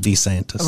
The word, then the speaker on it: DeSantis: How you pronounce it, DeSantis.